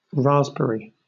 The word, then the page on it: raspberry: British English